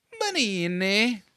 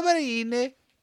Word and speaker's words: Benigni: I think the G is silent. Benigni. – Benigni. Roberto Benigni.